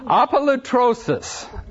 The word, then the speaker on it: apolutrosis: And our word here, our Greek word, oh dear, apolutrosis. Apolutrosis, okay? A-P-O, away from, lutrosis, L-U-T-R, long O, S-I-S, L A-P-O-L-U-T-R-O-S-I-S.